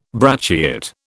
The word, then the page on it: brachiate: British English